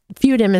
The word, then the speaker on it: Fudim: My name is Fudim, is spelled F-U-D-I-M.